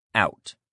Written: out

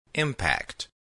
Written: impact

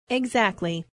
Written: exactly

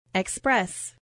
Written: express

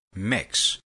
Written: mix

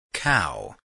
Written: cow

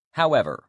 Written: however

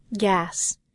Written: gas

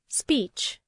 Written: speech

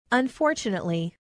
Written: unfortunately

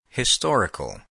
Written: historical

